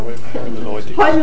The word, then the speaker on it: neurohermeneutics: He is now developing what he calls the new discipline of neurohermeneutics. I hope I pronounced that right. I'm German native speaker, so. – Hermeneutics. – Okay, it's like German. – It sounds German, it sounds better.